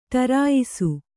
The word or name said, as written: ಟರಾಯಿಸು